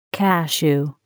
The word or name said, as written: cashew